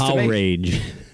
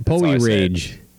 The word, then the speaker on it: Powerage: How about Poe Rage? – Nope. – Yeah. – Poe Rage? – You're putting it's not a good title. – You're putting the emphasis on the wrong syllable right there. – Yeah, if you say so. Yeah. We're off and running. I say it's Power Rage.